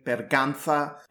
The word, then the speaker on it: Berganza: And by the way, she preferred the Castilian pronunciation, Berganza,